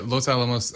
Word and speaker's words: Los Alamos: Yeah, Los Alamos and Wright-Patterson.